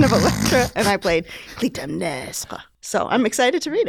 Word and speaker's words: Clytemnestra: Follows Electra, the daughter of Agamemnon and Clytemnestra. Clytemnestra. – I know you pronounced that in French because you were in a play about it. – Yeah, that's correct.